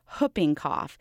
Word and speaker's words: whooping cough: The W in whooping cough is silence, as in the word who, so it should be pronounced whooping cough.